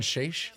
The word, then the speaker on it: Schaech: Schaech. Is that how you pronounce it? – This is a very important scene. – The guy who was married to Christina Applegate. – Schaech. Yeah. Jonathan Schaech.